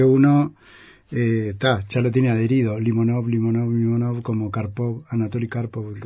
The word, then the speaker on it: Limonov: De la sintonía de Limonov, que vamos a poner el acento en la primera O, Limonov, porque no es francés.